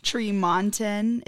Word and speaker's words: Tremonton: Tremonton, if you're ever wondering how you pronounce our small city in Utah. It's Tremont in Utah with only one E. – The other E is silent.